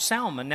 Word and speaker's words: Salmon: Now here on this one you do pronounce the S, all right? The L, excuse me. The fish, salmon, right? We don't pronounce it, right? It's not salmon patties, okay? But here that is the name, all right?